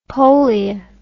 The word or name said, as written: polje